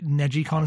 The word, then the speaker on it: NegiCon: NegiCon, is that how it's pronounced? – Yes.